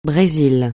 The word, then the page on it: Brésil: francais